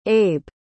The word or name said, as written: abe